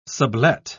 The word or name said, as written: sublet